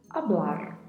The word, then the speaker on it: hablar: A particularidade de pronúncia fica aí a cargo do vibrato do R ao final da palavra, hablar.